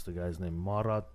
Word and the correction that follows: Murat: Okay, Marat or Murat. Murat, yeah, Murat, not Marat.